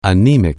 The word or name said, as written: anemic